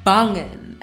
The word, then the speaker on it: banging: And the word I chose for myself was the word bangen. And you have to say it like that. The pronunciation, it just came to me, but we have to say it like that. It came to me unprovoked and it has to be said like that.